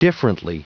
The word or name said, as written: differently